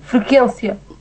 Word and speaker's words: frequência: Frequência. – Exatamente, frequência, consequência. Porque, supostamente, o U não se lê. – Supostamente o U do que acompanha o Q é mudo. – Sim. Pronto. Exatamente. – Quer dizer, é mudo mais ou menos, porque quando... – Não, não, não. É mudo com E com I.